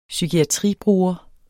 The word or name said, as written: psykiatribruger